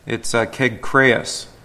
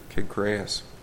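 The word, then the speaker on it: Kegkreas (Κεγχρεαῖς): We would say Synchrea, right? And that's pretty close. It's Kegkreas in Greek, Kegkreas.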